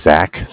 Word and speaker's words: Zach: So my name is Zach, spelled Z-A-C-H, with Bank of Cardiff. Cardiff is spelled C-A-R-D-I-F-F, as in Frank Frank.